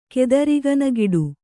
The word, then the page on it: ಕೆದರಿಗನಗಿಡು: kannada